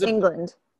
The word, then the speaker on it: Englund: But it's interesting that Robert Englund? Am I saying that right? – I think it's England. – England, like the country, but it's spelled Englund with a U.